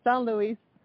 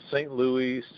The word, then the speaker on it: St. Louis: But I say San Luis. But I think you don't say it like this. It sounds too French when I say it. St. Louis? Like, St. Louis? How do you say it? – Yeah, St. Louis, St. Louis.